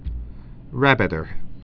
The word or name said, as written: rabbiter